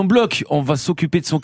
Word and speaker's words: Bloch: On dit Bloch, même si ça s'écrit Bloch, hein, B-L-O-C-H.